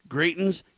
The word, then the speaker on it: Greitens: I guess Eric Greitens, I think is how you say his name. Greitens.